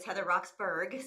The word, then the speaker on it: Heather Roxburgh: So as you said, Heather, it's Heather Roxburgh. So I'll just pronounce my name properly.